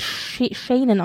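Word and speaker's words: Senan: Also Dominic West hat ja Charles gespielt und sein Sohn, ich glaube, er wird Shannon ausgesprochen, Shannon West hat in diesem ersten Teil, also Folge 1 bis 4, eben William gespielt.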